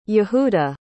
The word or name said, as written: yehuda